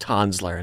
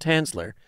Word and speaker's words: Tanzler: Karl Tanzler, and we learned it is pronounced Tanzler and not Tanzler.